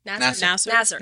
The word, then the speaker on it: Nassar: Nassar.